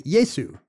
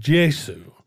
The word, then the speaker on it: Gesù: And today, you know, an Italian would just say Yesu, right? Like he would use the G. – Actually, in Italian now, it's pronounced Gesù, Gesù. So. – Oh yeah, G-E would be like J.